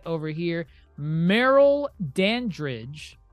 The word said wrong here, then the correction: Merle: I hope I pronounced her first name correctly. Merle, Merle. She plays Marlene.